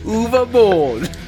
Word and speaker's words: Uwe Boll: That's how we've been told to pronounce it. Uwe Boll. – Nailed it. – It's like the Swedish chef.